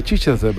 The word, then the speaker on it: Chichester: Nos hace gracia el nombre de Chichester, pero el nombre ya es de coña. – Esto, como el... Eso sería, se pronunciará Chichester o algo así, ¿no? – Chichester, claro.